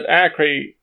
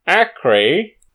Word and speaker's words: Acre: So Acre, you'll be pleased to know. Shit, Acre. Oh God, it's because it's spelt like acre.